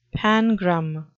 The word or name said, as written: pangram